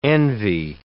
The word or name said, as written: envy